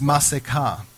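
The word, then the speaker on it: masekah (מסכה): But the word for the veil that was over Moses in Hebrew is masekah. And it's, I'll spell it for you, M-A-S-E-K-A-H, masekah.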